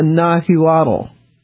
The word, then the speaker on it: Nahuatl: I mispronounced it. It's a Nahuatl.